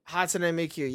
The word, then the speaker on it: Hatsune Miku: Hatsune Miku, am I saying it right? – Hatsune Miku, yep.